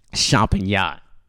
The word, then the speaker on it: Champagnat: Champagnat out of Hialeah. It's a small school. I'll spell it for you. It's C H A M P A G N A T. Champagnat.